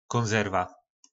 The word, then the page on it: konzerva: po našimu